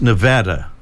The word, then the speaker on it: Nevada: I know we need to correct our pronunciation. It's Nevada, right? We found that out in recent times.